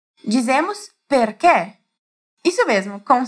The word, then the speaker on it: perché: Se aquelas pessoas fossem pronunciar essa palavra aqui, elas diriam perché. Mas agora você já sabe que não é assim, então você vai falar certinho.